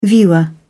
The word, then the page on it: viewer: British English